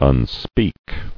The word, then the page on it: Unspeak: un·speak